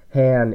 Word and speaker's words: Hann: Just a note, Han in all of those, my last name is spelled H-A-N-N, not just H-A-N, you know, like Andrew Han from Clipper Blog.